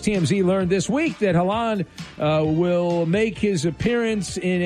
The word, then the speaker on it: Haaland: Story number two, a rising star, Erling Haaland, am I saying that right, of Norway, has been the breakout star Holland.